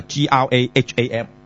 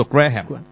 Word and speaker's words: Graham: 就係、是、叫做等我睇下先嚇，Graham Moore 啊，Graham 啊，留意下呢個字啊，到今即係而家當然啦，有啲人唔熟都讀錯㗎，Graham，個 H 係唔發音嘅，唔好讀 Graham，我以前都讀 Graham 嘅，即係好耐以前咧，但後嚟先知原來咧個 H 字係完全唔發音嘅，就叫 Graham Moore 就係 M O O R E 啊，咁又係好容易讀錯喎，因為你以為兩個 O 啊嘛。